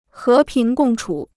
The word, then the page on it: 和平共处: hé píng gòng chǔ